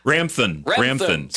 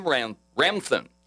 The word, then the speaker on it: Ramthan: Rathman. – Is it Rathman? Did I say that right? – It's like, Ramthan. Sorry. – Tim Ramthan.